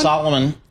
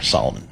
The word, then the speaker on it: Salomon: and that's helped by demand for Salomon shoes. So I ended up, did I mispronounce that one? – Salomon, those are the ski boots I wear, Salomon.